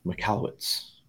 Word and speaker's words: Michalowicz: Michalowicz.